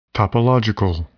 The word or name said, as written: topological